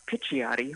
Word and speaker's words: Picciotti: So, I forgot to say, it was 25 years ago that Craig, Mark Picciotti, Mark Picciotti, which is kind of the Italian pronunciation, and myself, we did There Will Come a Day, which was from The Absolute, so we worked together back then.